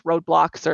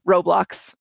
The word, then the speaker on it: Roblox: Most kids have played some form of video game, even if it's Roblox or, no, Roblox is how you actually pronounce it.